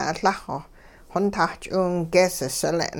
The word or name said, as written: Lah-xw xontah ch'iwingees sile'n